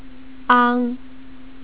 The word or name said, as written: ang